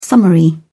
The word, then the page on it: summary: British English